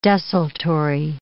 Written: desultory

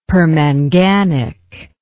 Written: permanganic

